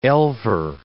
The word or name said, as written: elver